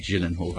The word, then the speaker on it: Gyllenhaal: Jake Gyllenhaal. – Gyllenhaal. – I think it's a soft G.